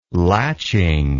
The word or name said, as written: latching